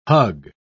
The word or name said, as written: hug